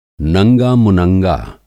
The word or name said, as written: नंगा-मुनंगा